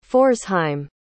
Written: pforzheim

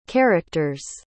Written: characters

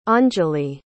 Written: Anjali

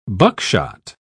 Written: buckshot

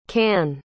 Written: cann

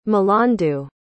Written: milandu

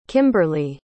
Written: kimberly

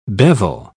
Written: bevel

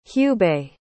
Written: hubei

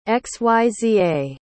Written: xyza

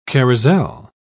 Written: carousel

